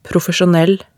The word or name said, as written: profesjonell